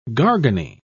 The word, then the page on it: garganey: British English